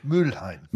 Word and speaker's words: Mülheim: Mülheim.